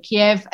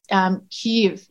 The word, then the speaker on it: Kyiv: Kiev, as I understand it, was the Russian pronunciation, and it's Kyiv in Ukrainian.